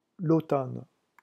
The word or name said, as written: l'automne